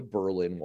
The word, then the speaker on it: Berlin: I saw the mask wall of America falling faster than the Berlin Wall did in 1989. You notice how I just pronounced that, not like the German city, but like the North Country town.